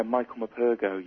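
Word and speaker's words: Michael Morpurgo: Yeah, Michael Mpago, yeah.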